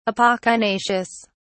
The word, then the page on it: apocynaceous: British English